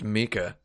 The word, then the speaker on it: Mika: Well, that would be pronounced Mika. – Yeah, Armika. – Yeah, but it looks like Micah.